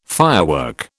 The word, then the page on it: firework: British English